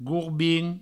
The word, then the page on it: gorbin: gourbin